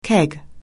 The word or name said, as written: keg